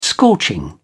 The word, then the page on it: scorching: British English